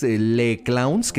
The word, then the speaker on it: Les Clowns: Tenemos la página que es Le Clowns, que se escribe Les Clowns, que se dice Le Clown.